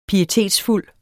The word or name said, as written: pietetsfuld